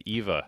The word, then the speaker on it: Eva: It's E-V-A, Eva. – Dude, I think I'm right. – Okay. – It's more of like a German pronunciation to say Eva than Eva.